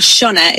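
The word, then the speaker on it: Sionna: Yes, well, in true Irish style, you look at it and it sounds nothing like what it's spelt like. But Shunna is how you pronounce it.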